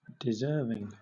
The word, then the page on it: deserving: British English